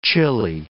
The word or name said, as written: chilly